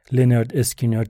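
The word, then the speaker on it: Lynyrd Skynyrd: با اسم عجیب لنرد اسکینرد تلفظ می شود پرونانس لنرد اسکینرد آگست 1973 با ژانر راک جنوبی منتشر شد. از اسم آلبوم معلومه که خیلیا نمیدونستن اینا رو باید چطوری تلفظ کنن.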